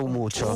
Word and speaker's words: Umuco: Umucho. – Esatto, che però si scrive Umuco. – Non lo ripeto. – Umuco. – Si scrive Umuco, ma si pronuncia Umucio.